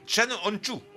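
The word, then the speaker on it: Can Öncü: Et ça s'écrit Can Onku. Donc Can C-A-N et Onku O-N-C-U. Mais ça se prononce Chan Onchu.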